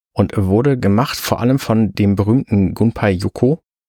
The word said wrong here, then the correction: Yokoi: Vergebt mir, wenn ich Namen falsch ausspreche. Ich bin Yokoi. – Was habe ich gesagt? – Yoko hast du gesagt.